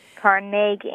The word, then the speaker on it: Carnegie: Carnegie, accent on the second syllable. – With more of an A than an E.